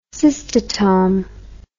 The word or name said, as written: cystotome